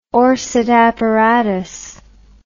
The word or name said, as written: orsat apparatus